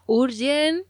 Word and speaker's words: Jurjen: Pues una preciosidad esta pieza, Urgen, que se escribe con J-U-R-J-E-N.